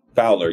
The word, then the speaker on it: Fowler: Fowler.